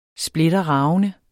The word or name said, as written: splitterravende